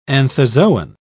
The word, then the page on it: anthozoan: British English